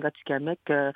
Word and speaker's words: Atikamekw: Et dans quelle partie du Québec, là, la langue Atikamekw, si je prononce mal, corrigez-moi s'il vous plaît. – Oui, c'est la langue Atikamekw.